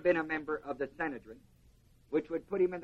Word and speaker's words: Sanhedrin: And the ruling body is called the Sanhedrin, or as it's sometimes pronounced, Sanhedrin.